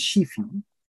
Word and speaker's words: Skyfri: So one other thing I had to tell you that Skyfree actually is a Norwegian word in a sense. So it's pronounced as Shifi. – Oh, she-free.